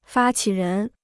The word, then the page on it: 发起人: fā qǐ rén